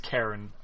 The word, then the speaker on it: Charon: Karen. Isn't that the ferryman? That Karen. Apparently, it's pronounced Karen. Karen. I know it's weird.